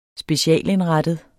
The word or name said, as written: specialindrettet